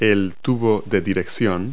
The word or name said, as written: head tube